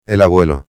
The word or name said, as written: el abuelo